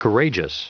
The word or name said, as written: courageous